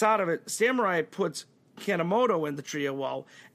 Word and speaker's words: Kanemoto: Then you have, how do you say it, Kanemoto? – Koji Kanemoto. – Kanemoto.